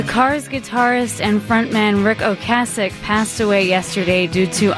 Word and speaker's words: Ocasek: My whole life I've said Rick Ocasek. Everybody today is saying Rick Ocasek because I guess that's how he pronounces it. The correct pronunciation is a soft A.